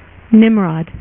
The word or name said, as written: Nimrod